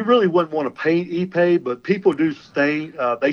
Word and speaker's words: Ipe: Yeah, and you mentioned ePay. That's spelled I-P-E. It's pronounced I-P-E. ePay, and yes.